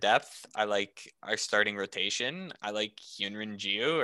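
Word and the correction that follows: Hyun-jin Ryu: or if I said his name wrong, Hyunjin Ryu. There we go. That's it. Hyunrin, imagine that.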